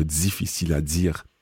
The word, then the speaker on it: difficile à dire: And you'll hear here where she says, uh, c'est difficile à dire, she really uses quite a, a Canadian pronunciation using the z for the di, so difficile à dire.